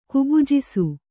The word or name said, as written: ಕುಬುಜಿಸು